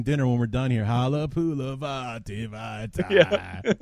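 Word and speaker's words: Halapoulivaati Vaitai: Obviously, he was an Eagles guy before, but if you say his name to play that funky music, white boy, you'll never not get it right. – Here we go. – So it goes, Halapuli Vati Vitae.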